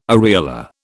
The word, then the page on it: areola: British English